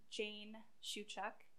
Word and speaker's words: Jane Shewchuk: So my full name is Jane Shewchuk.